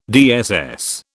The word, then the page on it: DSS: British English